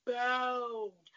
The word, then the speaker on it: bowed: And when I say bowed, I mean bowed with a long O.